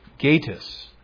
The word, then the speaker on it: Gatiss: This was written by a man named Lee Gatiss, that's how he likes people to pronounce his name.